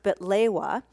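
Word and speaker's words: baklawa: And the Lebanese version, pronounced betlewa, is slightly different from the Greek baklava.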